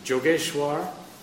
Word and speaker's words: Jogeshwar: And then I hear, Jogeshwar. In Bengali, pronounced wise like J, so it was Jogeshwar.